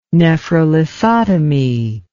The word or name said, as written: nephrolithotomy